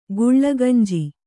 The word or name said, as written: ಗುಲಗಂಜಿ